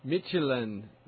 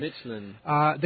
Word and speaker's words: Midtjylland: Midtjylland. – FC Midtjylland. Okay. – Midtjylland.